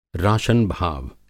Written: राशनभाव

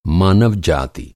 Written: जाति

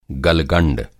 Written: गलगंड

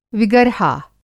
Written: विगर्हा

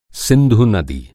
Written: सिंधु

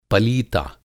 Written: पलीता

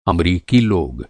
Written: अमरीकी

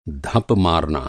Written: धप

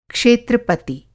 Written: क्षेत्रपति

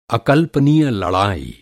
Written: अकल्पनीय

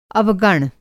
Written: अवगण

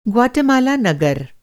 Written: ग्वाटेमाला नगर

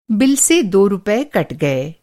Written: कटना

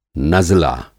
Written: नज़ला

